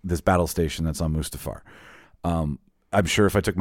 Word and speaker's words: Mustafar: And we were on, I always mispronounce it, Darth Vader's planet, Mustafar.